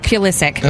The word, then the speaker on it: Pulisic: I said it right all morning, and now I forget how to say Pulisic. – It. Pulisic.